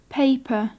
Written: paper

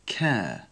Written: care

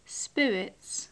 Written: spirits

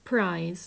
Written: prize

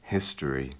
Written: history

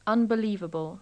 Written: unbelivable

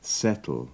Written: settle

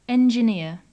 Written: engineer

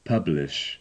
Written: publish

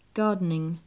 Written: gardening

